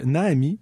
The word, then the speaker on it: Naami: On poursuit encore en nouveauté avec Naomi. Ça s'écrit N-A-A-M-I.